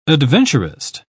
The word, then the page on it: adventurist: British English